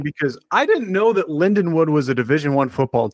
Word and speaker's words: Lindenwood: This is going to be Linwood in mid-September of 2027. What do you know about Linwood? – I know that they're pronounced Lindenwood. – Oh, yeah, sorry. My bad.